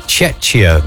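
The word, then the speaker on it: Czechia: I think you pronounce it Chechia now.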